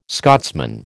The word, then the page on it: Scotsman: American English